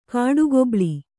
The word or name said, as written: ಕಾಡುಗೊಬ್ಬಳಿ